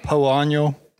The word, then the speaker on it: Poa: Cody as a marketer, you know, as the, was it called Poano? – No. – Poana? – Poana. How do you pronounce it? – Well, we just say Poa because it is hard to say, but there's Poa Trivialis and Poa Annua.